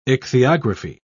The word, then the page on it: ichthyography: British English